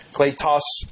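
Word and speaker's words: Kletos (κλητός): and that's wrong because the long sound is actually like our long A in the Greek, so it's really Kletos Apostolos.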